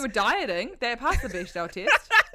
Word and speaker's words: Bechdel: It's like Bechdel.